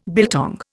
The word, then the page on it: biltong: Indian